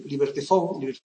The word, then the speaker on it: Liberty Fund: Está agotada, aunque la traducción inglesa la podéis encontrar en la web Liberty Fund, que es una Liberty Fund, se escribe de Liberty Fund, aunque se pronuncia Liberty Fund,